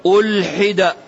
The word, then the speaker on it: ألحد: جابر يقول: إن النبي صلى الله عليه وآله وسلم ألحد. إيش معنى ألحد؟ انتبه أن تنطق الكلمة بالفتح، لا تنطق الكلمة بالفتح فتهلك. ألحد، إيش معنى ألحد؟